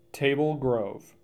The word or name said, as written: Table Grove